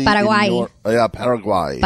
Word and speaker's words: Paraguay: Paraguay.